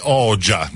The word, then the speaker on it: Oxa: Il suo cognome si scrive Rexha con la X e la H, un po' come Anna Oxa, però la pronuncia è corretta, mi dicono essere Regia, così come sarebbe Oja, no?